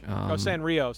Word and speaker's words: Sanrio: Hello Kitty Sonori. Extended... Hello Kitty Extended Universe. HKEU. Yeah, I don't like Animal Crossing that much. – Sanrio.